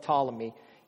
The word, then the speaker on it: Ptolemy: So Ptolemy, it's spelled with a P, but it's spelled Ptolemy, he ruled Egypt.